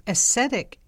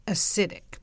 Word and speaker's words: acetic: Specific bacteria is added to convert alcohol fermentation into acetic acid. That's spelled A-C-E-T-I-C, acetic, not acidic.